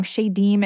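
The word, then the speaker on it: Shedim: And correct me if I'm wrong, Shadim is the plural and shade is the singular. Is that right? – Yes.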